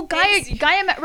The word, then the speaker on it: Gaia: It's spelled like Gaia, like the Mother Earth, whatever crap, you know, G-A-I-A. Yes, Gaia.